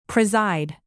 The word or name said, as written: preside